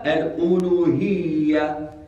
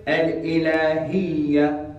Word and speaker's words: الألوهية: الأولى الألوهية first way الألوهية Second way الإلهية